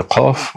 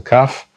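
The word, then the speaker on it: Qaf (קוף): That is to say, the Qaf, which is often mispronounced as a Kaf,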